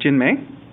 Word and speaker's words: Jinmei: And I'm sitting here with two of the three authors of some books on IPv6, Qing and Jinmei.